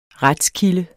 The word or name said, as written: retskilde